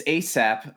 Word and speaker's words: ASAP: Um, but no, it's ASAP. Apparently, according to Wikipedia, it's ASAP, as in how you would pronounce ASAP as soon as possible, the abbreviation.